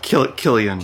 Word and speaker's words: Cillian: Cillian Murphy. – Yes, Cillian. But he pronounces it different. But anyway, but Cillian Murphy. – Killian. – Killian. Yeah, that's probably it.